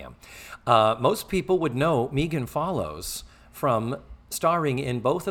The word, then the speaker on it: Megan: So the actress is Megan Follows, and it is pronounced Megan. That was put explicitly in the IMDb page about her.